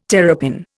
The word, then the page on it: terrapin: Indian